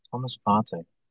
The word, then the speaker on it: Thomas Partey: Thomas Partey.